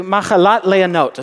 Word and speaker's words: Machalat Leonot: A song, a psalm of the sons of Korah, to the choir master, according to Machalat Leonot, is how that's pronounced in Hebrew.